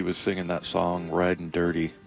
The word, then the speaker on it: Durde: He did say it's pronounced Durde, not Durde, which we all thought.